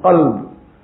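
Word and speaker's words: qalb (قلب): The very famous example of kalb and qalb. Qalb with a qaf means heart, and it goes with the calf, the small calf as we call it, then it goes to a completely different meaning, dog.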